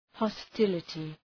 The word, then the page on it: hostility: hɒs’tılətı